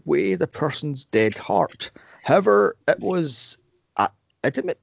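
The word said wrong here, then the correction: Ammit: Is that how you pronounce that? Adamant? Amit, yeah. Sorry, the damned souls, not Anubis.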